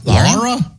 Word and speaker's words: Lara: You had a nibble of your, what is, how do you say that bar? – Lara? – Isn't it Lara bar? – Yeah. – How do you spell that? – L-A-R-A. – L-A-R-A.